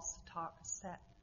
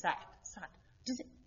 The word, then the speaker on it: Sartre: I think it's Sartre. Lauren, do you know French? Anyone know French? Sartre. It's S-A-T-R-E. And my sister said they always leave off the end.